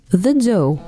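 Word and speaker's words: The Dø: The do происходит от первой ноты в сольфеджио до и произносится как английское слово do. Это о названии группы. The do – финско-французская инди-группа, основанная в Париже в 2005 году.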